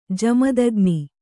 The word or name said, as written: ಜಮದಗ್ನಿ